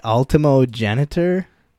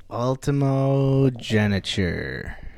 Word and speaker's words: ultimogeniture: Ultimogeniture.